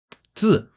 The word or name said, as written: zi4